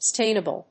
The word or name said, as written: stainable